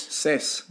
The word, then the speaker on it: seis: Seis. – It's Paul. Is that how you say it? Seis? – Seis. – Nice. You paid attention to Spanish.